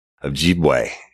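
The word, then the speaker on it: Ojibway: But it's Invisible Choir, and its host is Michael Ojibwe. And that's O-J-I-B-W-A-Y. And he says his name, he pronounces it just like it's spelled, right?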